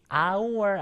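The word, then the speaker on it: hour: 얘는요, 철자로만 보면 H로 시작하잖아요. 전혀 모음이 아닐 것 같지만 발음이 our 아닙니까? 아, 모음 발음이기 때문에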